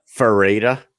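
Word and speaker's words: Loureda: Vareda? – Vareda? – Yeah, you're right. That is what it is.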